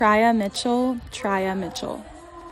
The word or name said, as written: Triya Mitchell